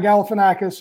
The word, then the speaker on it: Galifianakis: who's played by Zach Galifianakis, who's hilarious, is in the casino.